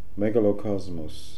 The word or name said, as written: Megalocosmos